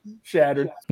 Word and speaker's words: Chadron: And, yeah, you did butcher it, but that's all right. You're not the first one. It's like Chicago. It's a shattered.